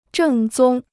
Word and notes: 正宗: zhèng zōng

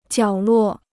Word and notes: 角落: jiǎo luò

